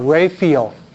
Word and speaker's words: Raphael: R-A-P-H. – A-E-L. Okay. You're mixing your Spanish with your English. It's R-A-P-H-A-E-L. Raphael. In Spanish called Raphael. In English you pronounce it Raphael.